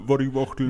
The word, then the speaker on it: Waddy Wachtel: Spricht man denn dann Waddy? – Nee, Waddy. – Also, Waddy.